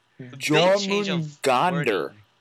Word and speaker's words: Jormungandr: Jormungandr.